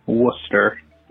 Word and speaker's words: Worcester: Very simply, Worcester.